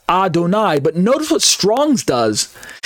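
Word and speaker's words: Adonai (אדני): pronounced Adonai because of the different vowel markings underneath the little consonantal letters, and pronounced Adonai because the Masoretic verbal tradition says it's Adonai, even without the vowel markings, right?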